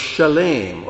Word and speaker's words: Shalem: It says he is Melchizedek, king of Shalem, or your Bibles will say Salem, but Shalem is how it's pronounced.